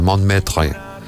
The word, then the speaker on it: Montmartre: que diría un francés bien pronunciado, Montmartre.